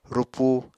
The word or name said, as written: rupu